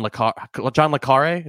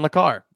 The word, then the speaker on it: le Carré: Is that how you pronounce it? I think that's the movie said accent, it. The movie said it, and I was like, oh, I thought it was John le Carré. I was wrong.